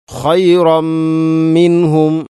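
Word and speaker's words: ഖൈറമ്മിൻഹും (خَيْرًا مِّنْهُمْ): അതുപോലെ തന്നെ തൻവീന് ശേഷം മീം, ഖൈറമ്മി. തൻവീന് ശേഷം മീമു വന്നാൽ ഇത് ഖാം കാമിൽ. പൂർണ്ണമായിട്ടും തൻവീനിനെ മീമിലേക്ക് കൂട്ടി ലയിപ്പിച്ചുകൊണ്ട് ഓതണം. ഖൈറമ്മിൻഹും.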